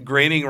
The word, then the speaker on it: Groening: As we heard, Groening rhymes with complaining in that interview we did with Paul Provenzano. – That's a good device to remember how to say Groening.